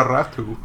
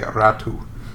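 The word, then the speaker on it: Räty: Yeah, Nora Ratu, yeah. It's a tricky one. It's spelled Ratti, R-A-T-Y, but because it's got the two little dots over that A, Tommy, you're supposed to say Ratu.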